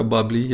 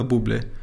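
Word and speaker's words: Bublé: Și reclama este fucking genială, este cu Michael Buble, care el nu știe să-și pronunțe numele și el spune că el nu vrea Bubbly, iar vrea Buble.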